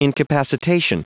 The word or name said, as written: incapacitation